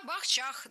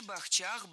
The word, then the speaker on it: бахчах: Маргарита сказала все правильно. Действительно, бахчи, бахчах, бахчами, бахчей. Вот только ведущая немного обсчиталась. Ударение во всех формах падает не на третий, а на второй слог. Причем в большинстве слов вообще всего два слога. Третьего просто нет.